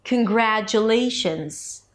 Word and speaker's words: congratulations: Not every syllable receives the same beat. So it's not congratulations, congratulations, but it's congratulations. Short, longer, short, longer, short. Ta da da da da. Congratulations. While the lay is the primary stress, it's also higher in pitch.